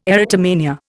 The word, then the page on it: erotomania: Indian